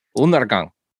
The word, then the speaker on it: Undergang: We just pronounce it like it's two N's, so it's Undergang.